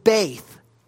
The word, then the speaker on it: beit: Now, that Hebrew word is the word beit, or Beth, as you might spell it in English, B-E-T-H. But we pronounce it Beth.